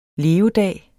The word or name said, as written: levedag